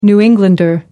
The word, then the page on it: new englander: American English